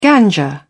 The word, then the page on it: ganja: British English